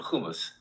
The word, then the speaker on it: humus: The top, the one layer is called humus, like the humus we eat, but it's not humus. Okay, it's spelled H-U-M-U-S, okay?